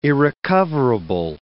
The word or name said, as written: irrecoverable